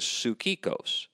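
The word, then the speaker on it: psychikos (ψυχικός): And if you saw the spelling, it's P-S-Y-C-H, so psych, psychikos. But the correct pronunciation of the Greek is psychikos.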